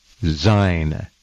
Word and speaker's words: Dzine: By the way, Zine is spelled D-Z-I-N-E, Zine, silent D.